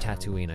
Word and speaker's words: Tatooine: Yeah, it's actually pronounced Tatooine, I looked it up.